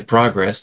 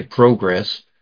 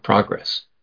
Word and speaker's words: progress: As we will see as we progress, I found watching some British police shows that their pronunciation, we say progress, they may say progress. And so sometimes I find myself stumbling over some words because my brain is saying, well, wait a minute, there's two ways of pronouncing this. Which one do I want to use? I'm trying to use the one we use in America, progress.